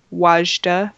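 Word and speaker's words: Wadjda: Blue is the Warmest Color and Wajda, that's how you say it. – I think so, yeah.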